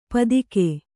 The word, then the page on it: ಪದಿಕೆ: padike